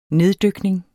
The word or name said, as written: neddykning